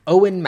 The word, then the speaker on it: Eoin: Oh, that's uh, pronounced Owen, by the way.